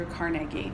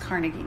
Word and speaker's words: Carnegie: And you guys know I'm from Pittsburgh, which is home of Andrew Carnegie, which is spelled, it's pronounced Carnegie, not Carnegie.